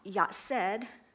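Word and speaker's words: yatsed (יצר): It's pronounced yatsed, close as I can get it.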